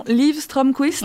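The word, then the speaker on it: Liv Strömquist: Alors, j'arrive jamais à prononcer son nom, Liv Stromquist.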